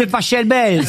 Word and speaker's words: Pachelbel: Cannelloni de Pachelbel. – S'il est allemand, ça se dit Pachelbel.